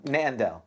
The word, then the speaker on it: Nandel: Nandel. Gandel. – Nandel. – Nandel. Is it a Nandel? – Don't you speak Gnomish? – Nandel. Nandel. – With a silent G.